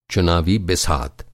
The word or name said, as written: चुनावी